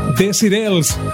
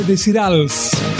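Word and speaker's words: Desireless: Ahora vamos a viajar hasta el año 1989 con Boayas Boayas, Desirels o Desirals, como tú quieras pronunciarlo.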